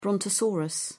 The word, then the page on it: brontosaurus: British English